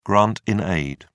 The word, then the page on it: grant-in-aid: British English